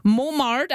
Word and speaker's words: Montmartre: She's telling us a bit more about her adventure to one of our province's mispronounced towns, Montmartre. I'm spelling it M-O-N-T-M-A-R-T-R-E.